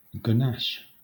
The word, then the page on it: ganache: Southern England